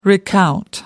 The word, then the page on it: recount: British English